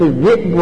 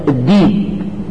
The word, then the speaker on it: الذئب: زي الذئب تنطق الذئب وتنطق الديب. فأكله الديب وما أنت بمؤمن لنا ولو كنا صادقين. هذه قراءة صحيحة وقراءة ورش وما زالت هي لهجة أهل الصعيد صعيد مصر. فورش كما صعيديا فهم لا يقول الذئب قط يقول الديب.